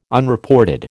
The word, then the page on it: Unreported: American English